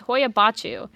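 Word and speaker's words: Hoia-Baciu: It's Hoyabatu. That's what it is. I looked it up. It's Hoyabatu.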